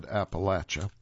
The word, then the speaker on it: Appalachian: And by the way, I said Appalachia or Appalachian Trail. There are people who say it should be pronounced Appalachian Trail. Why the difference? Locals call it Appalachia, so we will prefer or defer to the local pronunciation.